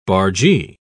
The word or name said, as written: bargee